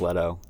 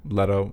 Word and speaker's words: Leto: Everyone I know says Leto. – Leto, okay. Jared Leto. We'll just go with Leto.